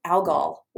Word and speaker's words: algal: It's algal oil, actually, is what how it's pronounced.